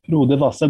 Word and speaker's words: Frode Vassel: Frode Vassel, I might be saying Frode's name wrong. Is that correct? – Frode Vassel, but he only is calling himself Frode as an artist.